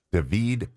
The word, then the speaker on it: David: Her mom is from David, Panama. David, not David. David. You got to say it right.